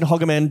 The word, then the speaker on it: Hogeman: Real pronunciation, Hogman, just like Pokemon.